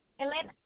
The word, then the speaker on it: Elena: Elena? Yes? Hi. Hello? – Yes, can I get your name, please? – Elena? – Can you spell that, please? – Elena. E-L-E-N-A? – Can I get your last name, please? – Macias, M-A-C-I-A-S.